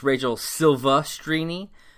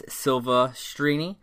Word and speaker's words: Silvestrini: He called her Rachel Silvestrini, and it's Rachel Silvestrini. There's no R. It's not Silver. It's Silvestrini.